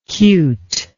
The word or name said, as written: cuteness